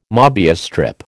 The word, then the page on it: MÔbius strip: American English